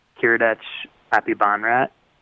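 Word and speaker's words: Kiradech Aphibarnrat: Kiradech Apibonrat.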